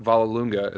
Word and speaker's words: Vollelunga: I met him, Mark Vallelunga is how you say his name, the guitarist from Nothing More.